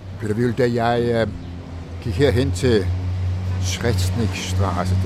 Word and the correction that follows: Schretzkistraße: det er næsten Schretzkistraße. – Schretzkistraße, ja. Det er jo ikke til at udtale, det er jo slet ikke til at stave.